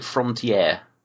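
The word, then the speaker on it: Frontière: Yeah, I saw this back in about 2009, and I came to this through another French film called I call it Frontier, which I think is the French pronunciation,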